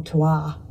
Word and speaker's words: terroir: And I would go as far to say that even if you're using exactly the same breakdown of grapes or varietals, they talk about terroir or terroir, if you're going to say it properly, which is like what the land and the weather and the sun brings to a particular harvest.